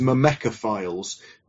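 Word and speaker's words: myrmecophiles: And the correct pronunciation is mimecophiles.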